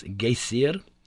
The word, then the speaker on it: Geysir: The geyser, a word the Icelanders pronounce geysir, and which signifies fury, rose majestically from its summit.